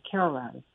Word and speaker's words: Cairo: So she spelled the same way as Cairo, Egypt, but pronounced Cairo.